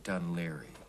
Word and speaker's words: Dun Laoghaire: I've forgotten how they pronounce the name of that place. – Dun Laoghaire? – Yes, that's right, Dun Laoghaire.